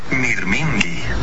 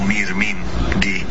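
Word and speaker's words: mirmingi (μυρμήγκι): Se dice mirmingi. Suena como mirmingi, más o menos.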